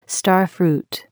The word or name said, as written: star fruit